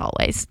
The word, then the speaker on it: Alvvays: It's spelled Alvvays, so it looks like Always, but it's Always.